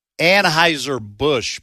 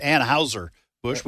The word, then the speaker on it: Anheuser-Busch: I have been mispronouncing Anheuser-Busch my whole life. I grew up pronouncing it Anheuser-Busch.